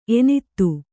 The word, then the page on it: ಎನತ್ತು: enattu